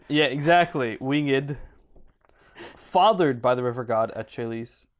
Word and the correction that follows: Achelous: Achelous, with the mother usually being cited as... – What? – Achelous.